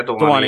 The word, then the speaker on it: Dhwani: But the name of the paper, and I hope I'm pronouncing it right, Dewani. Do I have that right? – That is correct. Yeah, Dewani.